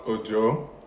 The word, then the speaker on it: Ojo: So my last name might be a bit harder. So it's pronounced Ojo. – Ojo. – But in my language, that spelling, so O-J-O, can mean different things depending on how you pronounce it. So Ojo means rain. Ojo is my surname. There's Ojo, which means tomorrow. So it's quite complicated.